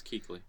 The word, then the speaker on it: Kuechly: That's how you say his name, right? – Yes, Kuechly.